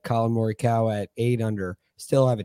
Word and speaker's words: Morikawa: You are now calling him Colin Morikawa, which is the proper way to pronounce that as opposed to Colin Morikawa. But if you hear him say it, it's Morikawa, which is hard, For my brain.